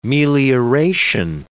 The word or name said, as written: melioration